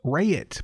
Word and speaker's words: Raitt: Raitt. Bonnie Raitt. – That, goodness. – I don't think you pronounce the I in Raitt. I could be wrong.